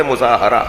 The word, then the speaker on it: مظاہرہ: یہ جو ہم مظاہرہ کہتے ہیں نا, یہ اس کا صحیح تلفظ ہے مظاہرہ, اس کا سامنے والے پر اپنے وجود کا اظہار کرنا.